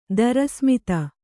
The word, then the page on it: ದರಸ್ಮಿತ: Kannada